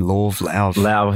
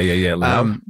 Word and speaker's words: Lauv: And then there's like great pop songwriters like Julia Michaels and Lauv and is that how you say his name? Lauv, I think so. – Lauv. – Yeah. Lauv. Yeah. – Who just get me when it comes to lyrics. H-E-R, just incredible soul.